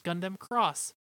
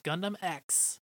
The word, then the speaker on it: Gundam X: Which, very interestingly, I thought it was pronounced Gundam Cross, but it's not. It's pronounced Gundam X.